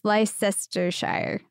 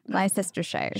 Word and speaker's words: Leicestershire: Yeah, it's Le- it's Leicestershire, Leicestershire.